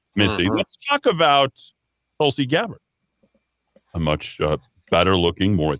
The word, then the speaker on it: Tulsi Gabbard: So Tulsi Gabbard, I know she is a former U.S.